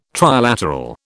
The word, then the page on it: trilateral: British English